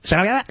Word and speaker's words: Sharia: and tried to ban Sharia law not a decade ago. – Hold on. I've been mispronouncing it. Sharia law. I didn't know that's how you pronounce it.